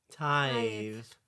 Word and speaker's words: tithe: How do you pronounce that word? – Tith? – Tith? Is that what it is? – Tith? Tithe.